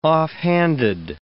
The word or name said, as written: offhanded